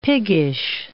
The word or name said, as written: piggish